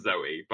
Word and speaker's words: Zooey: I know it's pronounced Zoe, but Zooey? – Oh, no.